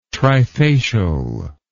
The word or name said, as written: trifacial